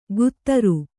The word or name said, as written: ಗುತ್ತರು